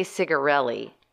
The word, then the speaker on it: tsigarelli: You tell me how to pronounce this word. T-S-I-G-A-R-E-L-L-I. – I would say cigarelli.